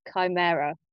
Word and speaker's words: Chimera: So it's called, I think it must be called Chimera, which is not how I'd been pronouncing it in my head, but it's by Alice Thompson.